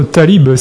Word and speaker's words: Talib: On va commencer avec Timshel Dakaer et son titre Talib. C'est bien comme ça qu'on prononce, Stéphanie ? – Oui, c'est ça.